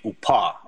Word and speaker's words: UPA: Well, you have to remember that the Ukrainian Insurgent Army, or UPA, UPA as it's pronounced, was not just a unit.